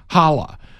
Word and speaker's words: Challah: dinner bread, the dinner rolls, Steve also informs me that, is it pronounced Shala? How is it pronounced? Challah. Challah bread, Steve says, looks so pretty on the dinner table.